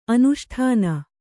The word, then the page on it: ಅನುಷ್ಠಾನ: anuṣṭhāna